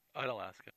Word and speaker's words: Unalaska: I believe they sailed from Seattle and stopped in, how do I pronounce it? Is it Unalaska or Unalaska? – Unalaska.